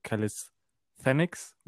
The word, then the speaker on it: Calisthenics: Calisthenics, wird das ausgesprochen. Ich habe es letztes Mal falsch ausgesprochen tatsächlich.